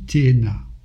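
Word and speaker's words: Téhéna (תאנה): Le figuier se dit Téhéna. C'est un Tav, un Aleph, un Noun et un He, Téhéna.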